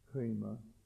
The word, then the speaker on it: Rhema: Dieses Wort ist Rhema, H-R-E-E-M-A, so ausgesprochen, Rhema.